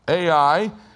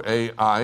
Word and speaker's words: Ai: They would capture the city of Jericho first, then they would move on to Ai. That's how you pronounce it. It's I in Hebrew. Ai is pronounced I in Hebrew.